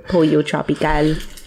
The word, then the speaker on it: Pollo Tropical: I don't know if that's how you actually pronounce it. – It is, because my supervisor told me so. – Okay. – Pollo Tropical. – Yeah. – Because she's Puerto Rican.